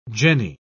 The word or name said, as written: jenny